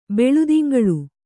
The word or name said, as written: ಬೆಳುದಿಂಗಳ್